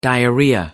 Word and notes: diarrhea: British English

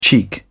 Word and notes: cheek: British English

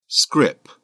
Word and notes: scrip: British English

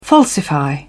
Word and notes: falsify: British English

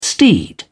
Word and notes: steed: British English

